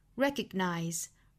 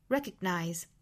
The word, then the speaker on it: recognize: Recognize, recognize. 当然这个 g 呢我们是不完全爆破的不能读成 g, g, 不能读成 recognize, 而是 recognize.